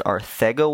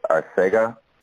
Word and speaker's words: Arcega: Is it Arthego Whiteside or Arcega Whiteside? The the correct pronunciation is Arcega.